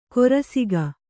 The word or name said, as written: ಕೊರಸಿಗ